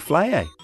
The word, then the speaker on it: Flayeh: Very close. Sadie Flayer. So more like rhyming with Slayer.